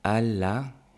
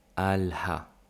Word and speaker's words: قال له: So one note here, قال له, he told her. We can also pronounce the ها. قال ها. It's up to you. Choose which one is less confusing.